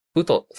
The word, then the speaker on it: Ut: で、ウト、最初のド、ドね、ドゥーって言ってるのは、 UT と書いてウトと読むんだけど、これをドゥーって読んでんだよね。